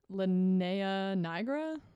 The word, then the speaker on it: linea nigra: I started to get the linea nigra, or linea negra, I think is how you say it, linea negra. – Linea nigra. – Yeah, that's exactly how you say it, actually.